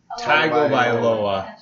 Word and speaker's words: Tagovailoa: How do you actually pronounce his last name? – Tagovailoa.